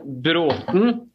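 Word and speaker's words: Bråthen: So my last name is then Brathen, which has this A with a round circle above it. There are three letters at the end of the Norwegian alphabet that you guys don't have, and they're pronounced a, e, o. And the last one then is right in the middle of my last name. It's an o.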